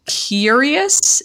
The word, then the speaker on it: QYURRYUS: And I believe it is pronounced QYURRYUS. It is spelled in all caps, QYURRYUS.